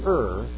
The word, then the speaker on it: err: But is it, if you're going to err, and by the way, that is the correct pronunciation, if you're going to err, then err on the side of worshiping God more so rather than less so.